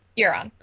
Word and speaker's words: Euron: Is that how you say it? Euron? – Euron, yeah.